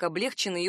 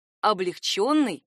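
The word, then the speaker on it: облегчённый: Ни в коем случае нельзя произносить их как облегченный и углубленный. Это такая же ошибка, как осужденный вместо осужденный. Правильные варианты — облегченный и углубленный.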